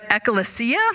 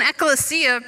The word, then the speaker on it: ekklesia: But in the Greek, the word church, which is pronounced, I'll try it, ekklesia, didn't really mean anything spiritual or Christian at all.